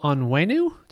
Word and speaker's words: Onwenu: Patriots putting left guard Michael Onwenu.